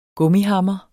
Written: gummihammer

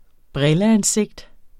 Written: brilleansigt

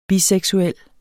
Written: biseksuel

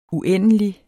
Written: uendelig

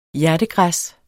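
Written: HJERTEGRÆSSET

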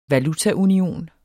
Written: VALUTAUNIONEN

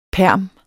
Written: perm

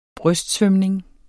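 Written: brystsvømning

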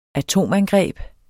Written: atomangreb

